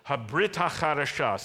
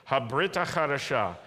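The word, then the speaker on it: Habrit HaChadasha (הברית החדשה): Habrit HaChadasha. Say that with me. Habrit HaChadasha.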